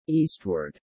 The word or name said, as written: eastward